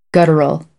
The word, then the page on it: guttural: American English; ɚ - əɹ